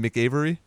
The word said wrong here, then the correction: McAvoy: He makes reference to Xavier, and he's like, so wait, McAvoy or Stewart? – Yeah, there you go.